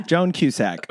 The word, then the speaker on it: Joan Cusack: John Kaser in the musical is fucking great. Kaser? I forget how you pronounce his name. He's great. – Joan Cusack.